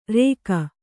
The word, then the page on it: ರೇಕ: rēka